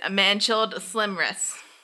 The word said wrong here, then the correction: manacled: How do you spell that? – M-A-N-A-C-L-E-D. – M-A-N-A-C-L-D? – E-D. – E-D. Manacled? – Manacled? Maybe. Well, maybe.